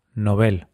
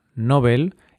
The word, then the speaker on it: Nobel: En su lengua de origen, el sueco, es palabra aguda, novel, y así se recomienda pronunciarla en español, a pesar de que la pronunciación llana, novel, está muy extendida, incluso entre personas cultas.